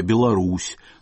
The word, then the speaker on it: Беларусь: Алма-Ата должна произноситься Алматы, Белоруссия – Беларусь, Таллин требовал добавить на конце вторую «Н».